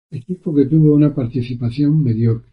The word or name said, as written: mediocre